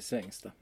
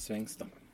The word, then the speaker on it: Svängsta: Den betoningen är extremt onaturlig för min dialekt. – Mm. Ja, precis. Alla säger Svängsta. – Ja, exakt. – Alla utifrån. – Ja. – När vi säger Svängsta.